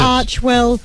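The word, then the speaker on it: Archewell: Archwell or Archiewell. – Archiewell, yes.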